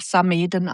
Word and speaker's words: Samedan: Eigentlich der deutsche Begriff ist Samaden. Und so wie wir schreiben, Samedan ist eigentlich romanisch, werden aber Samaden ausgesprochen.